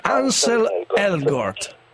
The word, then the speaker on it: Ansel Elgort: No, è americano, si pronuncia come lo leggi. – Ah, quindi Ansel Elgort.